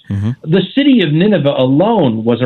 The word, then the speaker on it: Nineveh: Can you talk to us about Nineveh, if I'm pronouncing that correctly? – Yeah, Nineveh.